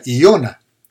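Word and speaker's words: Iona (יונה): Jonás, hermano, el nombre de Jonás viene de una raíz hebrea, hermano, que, se pronuncia Iona, con I.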